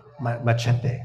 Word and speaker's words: Machete: Hang on, say it properly. – Machete. – There you go.